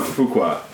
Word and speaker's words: Fuqua: Fuqua. – Fuqua.